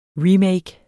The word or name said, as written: remake